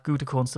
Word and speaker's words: Gutekunst: An awful lot of people asking, how do you pronounce it? Anybody who did German in school will know that Gutekunst is art. Gutekunst is what it is, but they call him Goody.